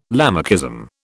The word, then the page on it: Lamarckism: British English